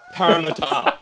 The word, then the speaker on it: Parramatta: That also explains why you constantly mispronounce Parramatta as Parramatta. – Parramatta. That's right.